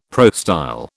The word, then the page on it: prostyle: British English